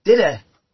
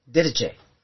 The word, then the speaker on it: Didache: I had a copy of the Didache different people pronounce it Didache. Didache.